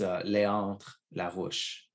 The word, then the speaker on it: Léandre Larouche: Yeah, so my full name is Léandre Larouche. – Léandre Larouche. – That's the French pronunciation.